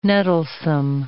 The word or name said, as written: nettlesome